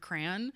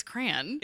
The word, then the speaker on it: crayon: It's just C-R-A-N, crayon. Yeah. – Although every single person that I grew up with is like, what's wrong with you? It's pronounced crayon. And I'm like, that's so hard to say.